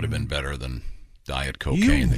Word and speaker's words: Cokagne: Yeah, I thought cocaine's a pretty good weight loss program as it is. – It's spelled C-O-K-A-G-N-E, but it would be pronounced cocaine, right? – Hmm.